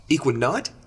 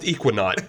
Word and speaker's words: Ekuanot: Now when you say not N-O-T or N-A-U-G-H-T? – Let me spell it for you because the spelling does not suggest the pronunciation. – Okay. – E-K-U-A-N-O-T. Equinot. – Oh boy. – Yeah. – Eskimo. – So if you see that out there, you don't have to sound like a Jamoke. – Yeah, you don't have to sound like a Jamoke. It's pronounced Equinaut.